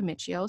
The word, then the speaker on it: Miccio: So today we have yet another guest and we have Brooke Miccio.